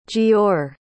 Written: jeor

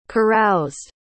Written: caroused

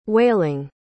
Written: wailing

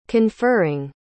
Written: conferring

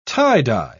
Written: tie-dye

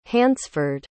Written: hansford

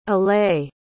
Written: ailat

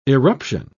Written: eruption

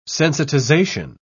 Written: sensitization

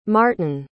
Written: martyn

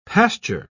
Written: pasture

